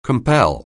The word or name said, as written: compatriot